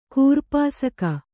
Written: ಕೂರ್ಪಾಸಕ

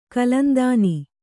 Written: ಕಲಂದಾನಿ

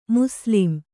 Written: ಮುಸ್ಲಿಂ

